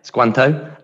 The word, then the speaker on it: Squanto: I was reading, there's one really important one, isn't there, called Squacho? – Yeah, Squanto and Samoset.